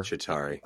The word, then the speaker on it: Chitauri: The Chitauri, is that how you say it? Are the enemies are the regular Chitauri.